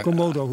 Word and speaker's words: Komodo: Hey, ehm, nu is intussen jullie eh, de dingen die ik eerder had, aangekondigd, hè, Bartodex, Corn Collect en Komodo, of Komodo zeggen jullie, hè? Ik zit altijd maar af te vragen waar moet ik eigenlijk die klemtoon leggen. – Eh, Komodo hoor ik jullie zeggen.